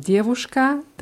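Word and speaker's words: Djewuschka (девушка): Bei der Aussprache bitte aufpassen und nicht mit dem Wort Derduschka verwechseln, denn Derduschka hieß Opa. – Opa. Und die Wörter unterscheiden sich nur durch einen Buchstaben. Djewuschka, der dritte Buchstabe ist W und bei Derduschka D.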